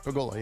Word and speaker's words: Pegula: Terry, how do you say it? Pegula? – Pegula, yeah.